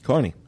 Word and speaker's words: Kearney: I have an email from Hector Torres from, I know I'm going to pronounce this wrong, it's Kearney, New Jersey? – Kearney, that's correct.